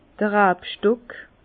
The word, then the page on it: d'r Rabstock: Bas-Rhin; Herrlisheim